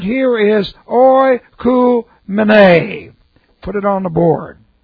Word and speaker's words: oikoumene: So here's our word, oikoumene.